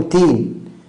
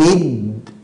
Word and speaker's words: الدين: يعني مالك يوم الدين يبقى مش يوم الدين بقى القيامة، ده يوم تين، لا الصواب مالك يوم الدين. طب ماذا حدث؟ الدال بدل ما احنا هنمسها عملنا لها جهر عكس الهمس. جهر يعني حبس النفس عند النطق بالحرف، يعني أد لكن كده حبست الحرف كده أد، لكن أت يبقى انت كده جريت النفس. إذن هكذا مالك يوم الدين الدين الدين.